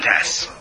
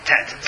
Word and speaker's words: tet: Nun tes. Everyone see it? – What is tes? – Tet, sorry. – Oh, tet. – Yeah, nun tet. – Yeah, tet. – My Ashkenazi pronunciation.